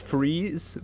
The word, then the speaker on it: Fries: We got it's pronounced Freeze, but it's spelled Fries. So like it just looks like it says Fries, Virginia.